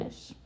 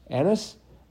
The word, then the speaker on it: anise: and nyes. You want to explain? – Anise. – Anise? I mm-hmm. always mispronounce that.